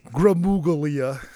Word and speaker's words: Gramuglia: Ant Gramuglia, in case you're trying to remember how to spell it, but the G is silent.